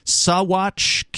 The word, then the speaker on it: Saguache: Saguache County, Colorado. It's a strange word. It's spelled S-A-G-U-A-C-H-E. I guess you don't pronounce the G in that.